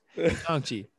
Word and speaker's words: Shang-Chi: Did I say it wrong? – You butchered the name. – Shang-Chi? Shanghai? – No. – What is it? – No. Shang? – Oh, Jesus. Shang-Chi. Sorry. Shanghai. Ooh, damn, that's way off. Shang-Chi.